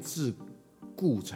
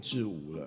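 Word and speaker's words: 桎梏: 应该是念智固才对啊，我念成智无了，嗯，其实还有个例子啊，像。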